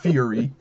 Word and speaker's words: Furi: F-U-R-I. – Yeah. – Not to be confused with furry. It's Fury.